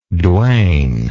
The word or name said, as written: Duane